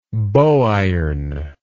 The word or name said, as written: bow iron